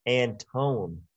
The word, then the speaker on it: Antone: It is TJ Anthony. – I wish you could say his name right. TJ Antone.